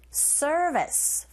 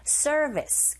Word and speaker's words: service: 잡아주시고 서비스가 아니라 우리식 발음이 아니죠. R이 들어가 있기 때문에 service 그리고 B가 아니라 V인 줄 역시 긁어주시고 service for the island I 모음 앞에 더는 D로 그리고 people 하시면 돼요.